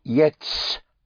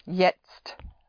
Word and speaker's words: jetzt: So it is not spelled that way, but that's how it sounds. Jetzt. It sounds like yes with a put the S between two T's, but one T before and one T after. Jetzt. In German, you have a Z instead of an S. Or Z. Z or Z. Jetzt.